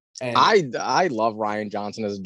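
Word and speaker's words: Rian Johnson: Yeah, Ryan Johnson.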